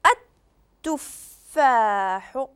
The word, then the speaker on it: التفاح: في السوقي، شوفوني كي نقصت، في السوق التفاح، نقصت من الالف للتاء، اللام ما كنطقهاش نهائيا، التفاح و الرمان، نقصت من الالف للراء، و النعناع، نقصت من الالف للنون لان النون حتى هي حرف شمسي.